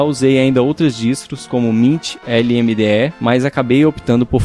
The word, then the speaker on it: Mint: Uma outra coisa que ia comentar é sobre a pronúncia de mint, que é mint e não mint.